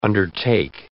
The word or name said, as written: undertake